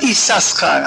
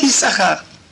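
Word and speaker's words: Иссахар: Но интересно, во всей Торе, без исключения, пишется Исахар два шин. Но читают только один, не говорят Исасхар, а говорят Исахар.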